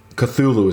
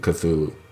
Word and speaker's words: Cthulhu: Cthulhu is actually how I should pronounce it. Cthulhu.